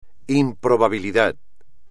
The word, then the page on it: improbabilidad: espagnol